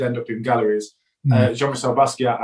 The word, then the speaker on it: Basquiat: It's Jean-Michel Basquiat, I think. – It's pronounced Basquiat. Haitian French, for the record.